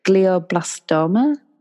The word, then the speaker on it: glioblastoma: She had, and it's, I can't pronounce the word very well, so apologies, but glioblastoma.